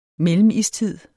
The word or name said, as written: mellemistid